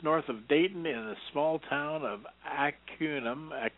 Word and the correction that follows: Arcanum: Arcanum, I believe it's pronounced,